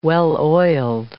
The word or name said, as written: well-oiled